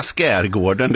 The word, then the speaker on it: skärgården: Jag bara, och det är ju bara skärgården, det är bara det ordet där jag uttalar SK på det.